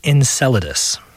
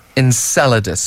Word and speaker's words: Enceladus: It's called Enceladus. – Enceladus.